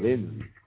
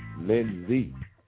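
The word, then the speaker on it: lundi: Don't read it how it looks or you'll be off, because this is Monday is spelled L-E-N-D-I, but it sounds like you're saying Lindsay. Lindsay. You know what I'm saying? Lindsay. Lindsay. L-E-N-D-I. Lindsay.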